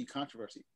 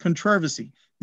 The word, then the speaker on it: controversy: Ready to rock, and it's pronounced controversy. – It's pronounced controversy in America. I don't know where you're coming from, bro.